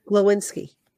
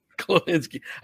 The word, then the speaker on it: Glowinski: Is that how you say it? – Glowinski. – Glowinski.